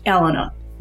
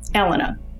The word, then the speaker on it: Alana: Before we go on, it's pronounced Alana. – Alana? – Alana. Just say Alan with a A at the end.